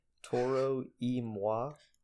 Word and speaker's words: Toro y Moi: I could murder this pronunciation, but I'm pretty sure it's pronounced Toro y Moi. It's Toro space Y space M-O-I. And I think it's like Toro like Spanish, and then Moi like French, I think.